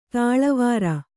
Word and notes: ತಾಳುವಾರ: tāḷu vāra

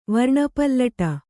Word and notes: ವರ್ಣಪಲ್ಲಟ: varṇa pallaṭa; kannada

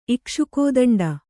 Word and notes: ಇಕ್ಷುಕೋದಂಡ: kannada

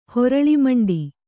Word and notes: ಹೊರಳಿಮಂಡಿ: Kannada; horaḷimaṇḍi

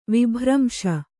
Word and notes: ವಿಭ್ರಂಶ: Kannada

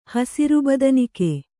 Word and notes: ಹಸುರುಬದನಿಕೆ: kannada

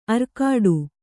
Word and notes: ಅರ್ಕಾಡು: arkāḍu

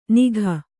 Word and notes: ನಿಘ: kannada

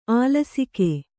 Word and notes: ಆಲಸಿಕೆ: kannada

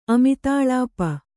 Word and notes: ಅಮಿತಾಳಾಪ: kannada

